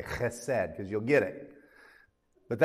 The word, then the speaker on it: chesed: In Hebrew, it's chesed. You have to say it like that.